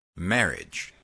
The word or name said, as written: marriage